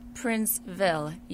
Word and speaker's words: Princeville: Princeville, yes. – Princeville. Okay, just as it reads.